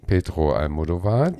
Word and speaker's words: Pedro Almodovar: Okay, dann hüpfen wir mal zu Pedro Almodovar, sie hat es richtig ausgesprochen.